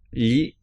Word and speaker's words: gli: Gli italiani. – And this gli is spelt G-L-I. And the pronunciation is a little bit different to in English, an English sound.